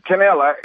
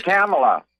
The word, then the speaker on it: Kamala: We've run into some people that think it's amusing to mispronounce her name, and it's not a difficult name to say. It's just Kamala.